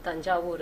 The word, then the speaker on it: Thanjavur: It's called, that place is called Thanjavur. It's in South India. It's in Tamil Nadu. The British used to pronounce it as Tanjo.